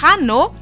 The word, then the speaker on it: hanno: und hanno, sie haben, zum Beispiel. – Aber das wird nicht ausgesprochen.